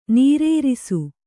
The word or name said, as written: ನೀರೇರಿಸು